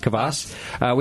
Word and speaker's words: kvass: It is kvass.